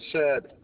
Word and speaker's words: Shed: First name Shed, S-H-E-D. Last name Jessup, J-E-S-S-U-P.